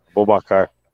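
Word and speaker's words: Aboubakar: A, Bobo, a Bobacur, sei lá como é que é, a Babacur, como é que fala? – Bobacar. – Bobacar, é quase igual, é.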